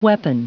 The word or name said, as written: weapon